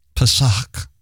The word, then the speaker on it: Pesach: And how do you say that word? – Pesach.